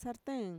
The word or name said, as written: sarteen